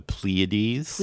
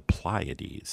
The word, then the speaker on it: Pleiades: Well, I pronounce it the Pleiades. – Pleiades, okay. – But an astronomer I follow on YouTube from England, they pronounce it the Pleiades.